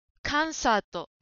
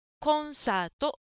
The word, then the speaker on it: コンサート: 4 番の答えのコンサートという言葉ですが、カンサートに聞こえました。コをはっきり発音しましょう。コンサート、コンサートです。